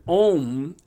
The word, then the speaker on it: om: Because, see, the word om actually is broken down into three syllables when it's chanted that sound like A U M.